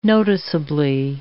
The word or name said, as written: noticeably